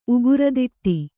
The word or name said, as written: ಉಗುರದಿಟ್ಟಿ